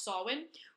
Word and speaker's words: Samhain: But anyway, he's actually based off of Samhain, which is spelled Samhain, but is pronounced Samhain, which is a pagan Celtic festival which celebrates the harvest and the dark half of the year.